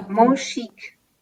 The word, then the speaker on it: Monchique: Musik, Monchique.